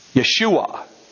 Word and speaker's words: Yeshua: Yeshua, the Hebrew pronunciation.